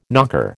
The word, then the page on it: knocker: American English